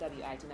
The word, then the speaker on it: Wit: So, my Instagram is naturallywit, NaturallyWit, because it's a nickname, and a nickname doesn't have to have a silent letter in it. So, yeah, it's WIT, not WHIT.